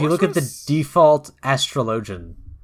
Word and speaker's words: Astrologian: Oh, no, I mean, like, it's pronounced Astrologian.